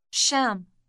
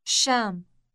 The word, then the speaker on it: الشام: الشام. الشام. – The sh sound at the beginning is rather long.